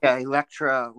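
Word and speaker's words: Elektra: Am I saying that right? Electrica? – Electra Records, yeah.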